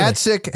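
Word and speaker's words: Gacek: Gatsek has a perfect 5-star rating on Google Maps. His name, pronounced Gatsek, means long-eared bat in Polish.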